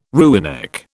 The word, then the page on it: rooinek: British English